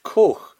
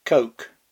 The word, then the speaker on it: Koch: Well, K-O-C-H is pronounced Koch in German and means cook. I've been here in the colonies long enough, though, to know that it's a serious faux pas, or as the Germans would say, ein Fehltritt, to pronounce German names the German way. My fallback instinct is just to anglicise the thing as Koch, but people tell me that doesn't work either. Apparently, it's Coke.